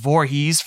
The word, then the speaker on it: Voorhees: One of the starting pitchers, Gage Voorhees from Northwestern High School. I think it's spelled a little different than um, Jason Voorhees, but it's said the same.